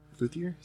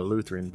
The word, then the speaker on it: luthier: He's a luthier. Luthier? Is that how you say it? – A Lutheran? – Nah, not a Lutheran, but a luthier.